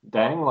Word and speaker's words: dengue: Dengue. – Dengue, like as in Denang, or...? – As in Dengrain from Survivor Philippines. – Oh. – JK pronounced it on the radio as dengue, but it's dengue.